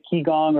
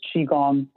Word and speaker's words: Qigong: So in the midst of this journey with your company, you were also developing yourself to be a Qigong practitioner. Am I pronouncing that correctly? – Yeah, some people pronounce it that way, Qigong, or what's popular in the West is Qigong as well.